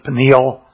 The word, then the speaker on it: Peniel: He says when he named it, we would typically, it's pronounced several ways, but we would commonly pronounce it Peniel.